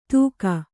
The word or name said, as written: ದೂಗ